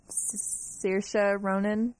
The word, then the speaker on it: Saoirse Ronan: It's Ryan Gosling's directing it, Christina Hendricks, I don't know how to say her name. Saoirse Ronan? – Yeah. – Yes, thank you.